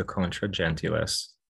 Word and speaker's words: Contra Gentilis: Contra, I say Contra. – It's contra gentilis. – It's like what, ecclesiastical Latin? – Yes. Yeah, I think most Anglos just say contra gentilis.